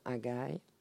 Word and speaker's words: Hagai: je ne sais pas si je prononce bien Hagai.